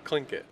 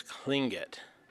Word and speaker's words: Tlingit: Tlingit. – The name is Tlingit. Just the way I hear my colleagues say the name. – That's Tlingit.